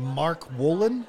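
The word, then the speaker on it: Mark Wolynn: How do you say his last name? Mark Wolin? – Yes.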